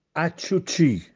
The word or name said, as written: Acciuccì